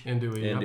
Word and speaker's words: Andouille: Andouille sausage? – Andouille.